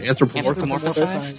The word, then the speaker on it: anthropomorphized: How do you say that? Anthropomorphized. Anthropomorphized.